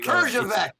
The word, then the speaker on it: Herjavec: I know it's Robert Herjavec. Right. – Herjavec. Yes. – Herjavec.